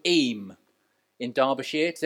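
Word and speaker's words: Eyam: Eam. I think that I Googled how you pronounce it. It's spelled E-Y-A-M. I'm saying Eam.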